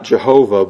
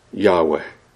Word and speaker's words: Yahweh: And now scholars believe that it's not Jehovah, but it's Yahweh.